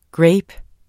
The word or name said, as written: grape